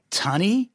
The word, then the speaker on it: Tonny: The reason I couldn't get his name right early on, it's not Sonny. It's Tony, T-O-N-N-Y, but they called him like Tunny.